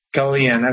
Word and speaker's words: Galijan: Galijan.